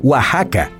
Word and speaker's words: Oaxaca: Y fueron mucho más allá y lograron que Xochimilco, Mexicali, Texas, Oaxaca y Jalapa mantuvieran la X, aunque se pronuncian con una J también.